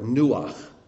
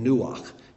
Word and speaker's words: nuach: And the word there for rest is the word nuach, which is like Noah, but it's a verb form. He's saying, I will rest you. I will make you rested. So that's why it's spelled a little bit different, but it sounds much the same, nuach instead of noach.